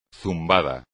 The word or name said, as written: zumbado